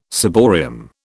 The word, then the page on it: ciborium: British English